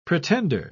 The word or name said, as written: pretender